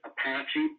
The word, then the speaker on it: APACE: And they have a series of studies under the acronym, I pronounce it Apache, but it's APACE.